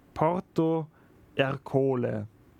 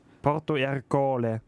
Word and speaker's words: Porto Ercole: 1608 flieht er quasi von Malta und 1610 stirbt er in Porto Ercole. – Also in irgendeiner Hafenstadt höchstwahrscheinlich. – Ja. Ja. Willst du nochmal die Aussprache kontrollieren? – Stimmt. Alles gut. – Porto Ercole.